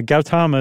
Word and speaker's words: Gautama: Yeah, Gautama is everything I saw.